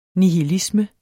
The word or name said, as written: nihilisme